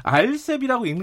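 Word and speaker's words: RCEP: 아, 근데 그거 알셉이라고 읽는 게 맞긴 맞아요. 이게 좀 예, 헷갈려요.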